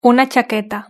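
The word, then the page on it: una chaqueta: Spanish (Spain)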